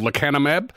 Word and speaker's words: lecanemab: I'm going to have a go that it's called lecanemab. – Correct. Well done.